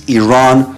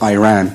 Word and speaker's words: Iran: Coming up at 12 noon, it's the USA taking on Iran. – You're pronouncing our country's name wrong. Our country is named Iran, not Iran. Please, once and for all, let's get this clear.